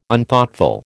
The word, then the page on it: Unthoughtful: American English